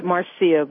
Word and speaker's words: Marcia: I pronounce it Marcia, but I know it's spelled M-A-R-C-I-A.